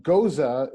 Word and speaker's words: Gose: So Goza is spelled G-O-S-E. You know, like goes. Pronounced Goza in German.